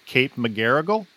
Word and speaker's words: McGarrigle: I mispronounced it the last time. Kate McGarrigle? Is that right? – Did I say it right? I'll accept that answer.